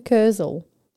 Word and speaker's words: Kurzel: Justin Krulls, which I've probably butchered. I butchered the whole name. Fuck it. Jesus. Sorry. – Justin Kurzel.